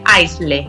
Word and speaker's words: aisle: Lo deletreo porque no se escribe así, ¿vale? Aisle. A-I-S-L-E. Escribe aisle, ¿vale? Pero se dice aisle, ¿vale?